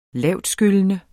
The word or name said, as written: lavtskyllende